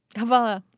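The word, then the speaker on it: hvala: Havala.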